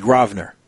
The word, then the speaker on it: Grosvenor: But I was being told that it's not Grosvenor, it's Grosvenor. So... until the S is silent.